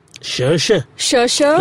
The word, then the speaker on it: Saoirse: Saoirse. Like, think of an Irish accent and just say it.